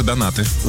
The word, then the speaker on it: донаты: А все на наши донаты. – Да? – Конечно. Мы всегда пожертвования оставляем. – Послушай меня внимательно. Когда приносишь донаты, да, о животных, ударение, правильно? Донаты. Вкусные, с начинкой.